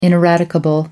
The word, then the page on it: ineradicable: American English